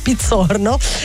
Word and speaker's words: Pizzorno: dove il chitarrista Serge Pizzorno, ovviamente insomma il cognome dichiara origine italiana, perché andrebbe letto in cognome italiano però con una pronuncia americana, no vabbè, lo diciamo direttamente in italiano, Pizzorno.